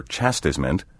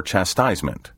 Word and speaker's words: chastisement: The corresponding noun may be pronounced either chastisement or chastisement. Chastisement is the traditional pronunciation. Chastisement has been recognized by dictionaries since the 1940s.